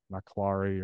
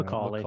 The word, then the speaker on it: Macaulay: I can't pronounce the name, Maclary or something like that. Macaulay. Macaulay.